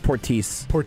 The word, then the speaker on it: Portis: It's pronounced Portis.